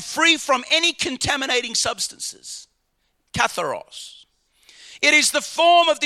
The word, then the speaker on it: katharos: The Greek word pure, blessed are the pure in heart, is the word katharos. And that word, how do you say it, Brother Othin? Katharos. You got it.